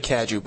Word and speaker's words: Cadieux: It's actually Cadieux, but Cadieux.